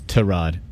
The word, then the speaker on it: Tyrod: He's like, actually, my name is Tyrod. People are like, what? It's like, yeah, it's Tyrod, not Tyrod.